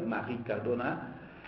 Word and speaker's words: Marie Cardona: Então, só para fazer o resumo, ele vai à praia, reencontra a Marie Cardona, né, pronunciado do jeito que um francês faria, Marie Cardona,